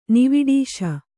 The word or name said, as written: ನಿವಿಡೀಶ